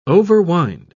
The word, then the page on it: overwind: British English